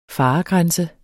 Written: faregrænse

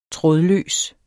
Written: trådløs

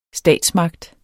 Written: statsmagt

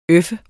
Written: øf

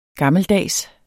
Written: gammeldags

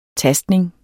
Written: tastning

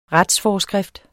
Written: retsforskrift

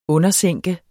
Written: undersænke

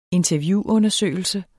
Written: interviewundersøgelse

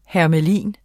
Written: hermelin

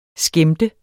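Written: skæmt

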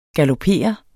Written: galopere

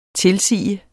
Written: tilsige

